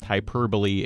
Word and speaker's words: hyperbole: It's pronounced hyperbole, H-Y-P-E-R-B-O-L-E.